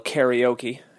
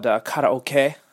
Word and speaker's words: karaoke: You know, first of all, it's not called karaoke, you know, it's called karaoke, right? Which is, you know, different pronunciation. That's how they do it here in Japan.